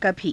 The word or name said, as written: kabhii